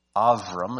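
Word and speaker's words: Avram (אברם): Avram is how it's pronounced in Hebrew.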